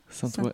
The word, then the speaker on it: Saint-Ois: Alors, j'ai un petit doute aussi sur comment le prononcer. Level One Saint-Ois. – Saint-Ois, nickel. – Saint-Ois. – C'est comme ça.